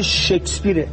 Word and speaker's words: شکسپیر: بعد تا بالاخره یکی از هنرمندا گفت آقا به خدا تلفظ این واژه شکسپیره. اول از همه شما به عنوان وزیر فرهنگ این مملکت که ما متأسفیم که ما وزیر واسیم، اول از همه یاد بگیرید که نام این آدم رو درست تلفظ بکنید.